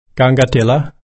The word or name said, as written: kangattilla